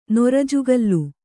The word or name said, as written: ನುರುಜುಗಲ್ಲು